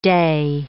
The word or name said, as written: dey